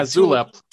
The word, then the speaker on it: Zulip: What other, so you mentioned River, which again, this is R-Y-V-E-R, not River. We talked about something called Zulip. Am I saying it correctly? Zulip. – Like, yeah, Zulip. – Tulip with a Z. – Tulip with a Z, yeah.